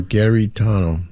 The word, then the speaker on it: Garitano: My sister Laura has a request for a brother, Giritano. – How do you spell it? – Giritano? No, Garitano. – Garitano.